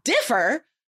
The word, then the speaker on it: differ: Differ, syllable, first syllable is stressed, and defer, that's like a, it's a different verb. It's totally different.